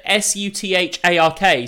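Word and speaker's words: Southwark: It's South Wark, it's spelling, but I've said it several bad ways. I definitely. What? How would you pronounce it? Suthark.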